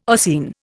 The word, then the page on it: ossein: Indian